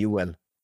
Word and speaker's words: Joel: In my language, in Dutch, it would be Joel, honestly, your name. Joel. – In Swedish, yeah. It would be Joel. So you're not far off.